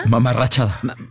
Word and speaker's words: mamarrachada: Luego dice, como dice Poncho, es una mamarruchada. – Mamarrachada. – Bueno, pues es lo mismo. ¿Vean el diccionario? ¿Cómo se dice? ¿Mamarrachada o mamarruchada? – Yo digo mamarrachada, pero es que no sé de qué manera esté bien dicho.